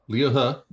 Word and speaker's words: He: Liu He, yep. – Huh, which is spelled H-U, ladies H-E. – And gentlemen. Huh? H-E? – H-E, yeah. – He. – He. – Huh. Huh. Yeah, that right.